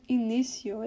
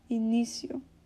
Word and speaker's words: início: So when he says, bem no início da história ocidental, he's saying at the beginning of Western history, this word início, it has a stress in the middle of the word, because, you know, you're supposed to put the most emphasis on the middle syllable, início.